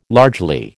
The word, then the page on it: largely: American English